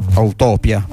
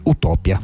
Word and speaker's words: Utopia: Una Björk alquanto gorgheggiante in questa utopia, utopia, anzi utopia. L'accento va in modo diverso rispetto all'italiano.